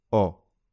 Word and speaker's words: ho: The H is always silent in Italian, but learners often make the mistake of pronouncing it. Just remember that I have is o. So if you sound like Santa, ho ho ho, that means you're saying it wrong.